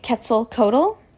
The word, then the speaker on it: Quetzalcoatl: Now, there was a god, we talked about him earlier, and he went by Quetzalcoatl. That's the most common pronunciation I hear.